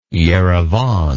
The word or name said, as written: erevan